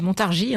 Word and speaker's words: Montargis: On découvre que Montargis, on dit Montargis, hein, c'est bien ça, on ne prononce pas le S,